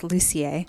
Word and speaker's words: Lucier: No, it's French-Canadian, so it's Lucier.